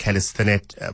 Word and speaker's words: calisthenics: Is a chair of the South African Street Workout and Calisthenics Association. Help me with the pronunciation of that word, please. – Hi. It is a bit of a tongue twister. It's calisthenics.